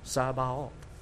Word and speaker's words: Sabaoth: In three syllables, Sabaoth.